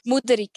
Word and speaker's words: Mutterig: Well, how do you say Mutterig? – Mutterig. – Mutterig. Like that? – Pretty much, yeah. – Pretty much. – Like more with the ooh, it's not ooh.